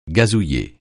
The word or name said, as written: gazouiller